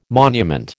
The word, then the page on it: monument: American English